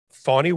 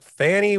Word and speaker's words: Fani: We've been talking about her so long, we finally figured out how to pronounce her name, because in the beginning it was like Fannie Willis in Fulton County.